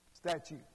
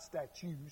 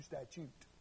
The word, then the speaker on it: statute: Oh, statute, excuse me. I said that wrong, didn't I? Statute. Okay? Not statue, statute.